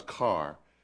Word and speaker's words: Kerr: Now, by the way, you've said a couple of times Carr. Is that the North Carolina pronunciation of Kerr? – Yes. – So you're referring to K-E-R-R?